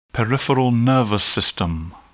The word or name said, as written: peripheral nervous system